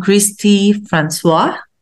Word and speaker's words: Christe Francois: So without further ado, let's welcome Christy Francois.